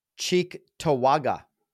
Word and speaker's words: Cheektowaga: Cheektowaga.